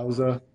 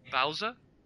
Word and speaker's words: Bowser: Bowser. – Bowser. – Yeah. – Is it kind of like Bowser but with an "uh"? – Yeah.